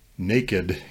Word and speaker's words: naked: He points out that though we say the word naked in two syllables, uh, the original meaning of the word is in one syllable, naked.